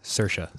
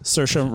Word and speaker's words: Saoirse: Saoirse, is that how you pronounce her name? – Saoirse. – Saoirse Ronan. Oh, cool. Saoirse Ronan.